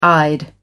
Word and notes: eyed: American English

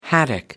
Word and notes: haddock: American English

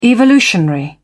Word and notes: evolutionary: British English

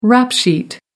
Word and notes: rap sheet: American English

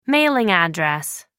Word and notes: mailing address: American English

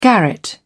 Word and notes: garret: British English

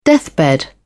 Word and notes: deathbed: British English

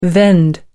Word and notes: vend: American English

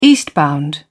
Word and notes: eastbound: British English

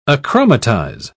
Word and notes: achromatize: British English

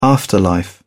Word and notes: afterlife: British English

